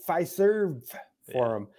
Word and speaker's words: Fiserv Forum: How do you say it? Pfizer? – Fiserv Forum.